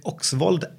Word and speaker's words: Oksvold: Uh, eh, Dag-Erik Oksvold och Anne Fagermo.